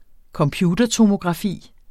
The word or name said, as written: computertomografi